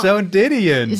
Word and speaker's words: Joan Didion: Aber natürlich Jean, kenne ich Joan Didion.